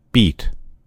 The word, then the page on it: beat: American English